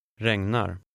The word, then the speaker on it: regnar: Number 5 has the GN combination, which you learned earlier. – Läs nummer 5. – Regnar.